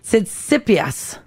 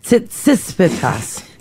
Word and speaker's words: Tsitsipas: Tsitsipas. – All right. – Tsitsipas.